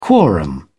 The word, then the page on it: quorum: British English